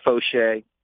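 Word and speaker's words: Faucheux: That's the correct pronunciation, Fauché.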